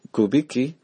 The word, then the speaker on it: Kubicki: Aquí en Estados Unidos, el que lo lleva es el padre James Kubicki, se apellida, o Kubicki es un apellido polaco, se escribe K-U-B-I-C-K-I, Father James Kubicki.